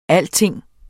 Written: alting

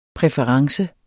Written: præference

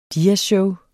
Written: diasshow

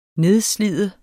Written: nedslide

